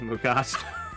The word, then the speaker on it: Mugato: And behind door number three, I hope I'm pronouncing it right, the Mugato. – Yep. Mugato, yes.